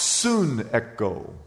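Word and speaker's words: soon echo (συνέχω): S-U-N, like the sun, and then echo. It's pronounced soon echo.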